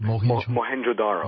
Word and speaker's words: Mohenjo-Daro: Mohenjo-Daro.